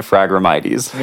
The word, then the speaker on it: Phragmites: If you read it how it's spelled, it says Phragmites. – Okay. – We plugged it into Google and clicked read. It said Phragmites. And I've heard it pronounced from a few experts calling it Phragmites. – Yeah. – So I think that's what we're going to go with.